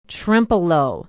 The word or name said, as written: trempealeau